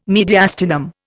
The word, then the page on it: mediastinum: Indian